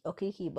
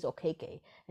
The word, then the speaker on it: Okeke: I thought it was Okeke, but apparently it's Okeke, and he's been making people like they said on the broadcast that he wanted people to pronounce it correctly.